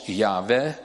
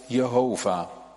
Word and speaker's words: Yahweh: Deze 4 letters vormen de heilige naam die uitgesproken wordt als Yahweh of Jehovah.